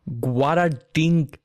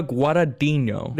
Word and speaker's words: Guadagnino: Luca Guadagnino. – Guadagnino? – Guadagnino. Luca Guadagnino.